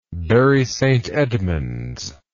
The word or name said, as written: bury st edmunds